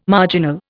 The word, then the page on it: marginal: Indian